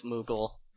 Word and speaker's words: Moogle: What about the Hello Kitty, Moggles? Okay, I know it's Moogle. It's Moogle like Google, right?